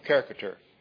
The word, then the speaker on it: caricature: I said it wrong a while ago. Caricature.